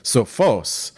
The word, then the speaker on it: Sophos: and he insisted that it's Sophos. So we're not even putting the emphasis on the right syllable.